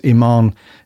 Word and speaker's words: Iman: I think I'm saying this right. Iman, it's not E-Man, is it? That sounds like an 80s cartoon. It's Iman Esfandi,